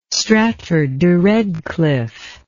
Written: stratford de redcliffe

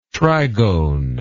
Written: trigone

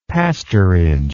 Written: pastorage

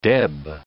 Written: deb

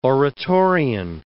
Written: Oratorian

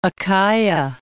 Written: Achaia

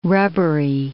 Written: rubbery

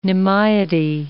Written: nimiety

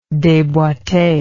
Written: deboite

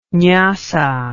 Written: nyasa

